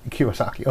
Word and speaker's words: Kiyosaki: That could be anyone from, like, a Dave Ramsey to a Robert, I can't pronounce his last name, Chikakwak. Very famous writing book called Rich Dad, Poor Dad. Then there's other Kiyosaki. – Ones I just watch for. – Kiyosaki. – Yeah. Kiyosaki.